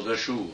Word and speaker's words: Dachau: Dachau, c'est ce qu'on appelle communément Dachau, hein, parce qu'en allemand, ça se lit, le U prononce OU. Ça fait Dachau. Puis le CH fait R.